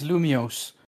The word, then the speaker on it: Lumiose: How do you say a city again? Lemiosin. No, but. – Well, the region is Kalos. The city is Lumiose.